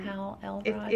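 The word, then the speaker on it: Hal Elrod: Yeah, Hal Elrond.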